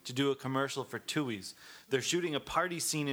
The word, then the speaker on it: Tooheys: I saw the other day they're doing a casting call for hipsters, and let's see, the beer company Tooey's. Have you ever heard of Tooey's beer? – How do you spell it?